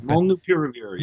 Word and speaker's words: molnupiravir: government has a $1.2 billion contract with Merck for, is it I can't pronounce it. – Molnupiravir. Yeah.